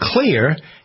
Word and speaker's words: Xlear: There's a product called Clear. You've probably heard me talking about it, spelled X-L-E-A-R.